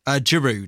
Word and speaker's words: Giroud: Giroud.